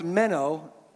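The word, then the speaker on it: meno (μένω): It's pronounced meno, not like the little fish. M-E-N-O, you might say.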